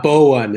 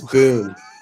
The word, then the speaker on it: Boone: It's not Bowen. It's Boone. Like Daniel Boone, the hunter. Or Boone, Iowa.